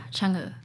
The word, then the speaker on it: Chang'e: Yeah, Chang'e.